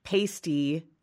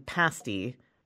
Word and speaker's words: pasty: In the Midwest, there's also this food term with two pronunciations, pasty and pasty. People mix them up sometimes. One is, you know, kind of a breast cover, obviously. The other is a baked pastry.